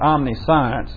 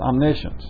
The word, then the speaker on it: omniscience: By the way, that's not omniscience. It's pronounced omniscience.